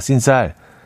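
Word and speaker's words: sin sal: 꼭 씬쌀 씬쌀 맞나요? 발음 씬쌀 스페인어로 소금 빼고 라는 뜻이랍니다. 하셨어요. 아, 신쌀.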